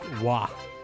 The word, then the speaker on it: Roy: Jason says that Patrick Roy is pronounced Wah.